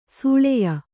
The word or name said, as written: ಸೂಳೆಯ